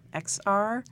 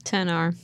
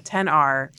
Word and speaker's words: XR: XR? – 10R. – Yeah, 10R.